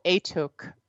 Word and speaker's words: Atuk: And it's called, I think this is how you pronounce it, Aituk? A-T-U-K. – Aituk.